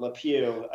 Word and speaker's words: LePeau: Well, I mean, the first thing that jumps out is because the way my name is spelled, LePeau. I mean, I was Pepe LePeau, but I always liked Inspector Gadget.